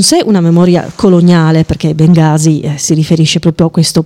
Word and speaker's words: Bengasi: E io continuo a pronunciarla alla torinese, chiedo scusa agli ascoltatori, magari quelli che ci tengono la pronuncia più corretta, però io dico sempre se vuoi andare a Bengasi prendi l'aereo, se vuoi andare a Bengasi prendi la metro.